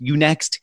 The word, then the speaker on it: UNext: By the way, I kind of feel like your turning point was it pronounced Unext? The online university, like University Next, UNext.